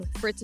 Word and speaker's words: Fritts: Fritz is spelled F-R-I-T-T-S because some people want to be butchering my name.